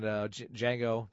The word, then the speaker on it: Django: Django, Django, Django. – D is silent. Django.